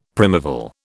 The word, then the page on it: primeval: British English